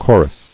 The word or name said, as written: Choruses